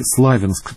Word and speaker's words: Славянск: Алекс Нойд упомянул Славянск или Славянск, так обычно ставят ударение местные жители.